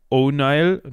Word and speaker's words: O'Neill: Ähm, aber, äh, ja, es ist also, im Englischen wird es O'Neill ausgesprochen.